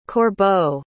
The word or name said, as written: corbeau